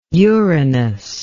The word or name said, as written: uranous